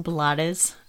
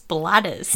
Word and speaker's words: Blades: I believe that's pronounced Blades. – I'm going to call him Ruben Blades. It sounds fake, but... – That's because it's Blades.